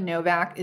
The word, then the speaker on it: Nowak: You know the spelling of Novak is N-O-W-A-K? – N-O-W-A-K. – Oh, okay. N-O-W-A-K. Like Nowak? – Yeah. – They pronounce it Novak. Okay. Did you know that? – No, I did not know that.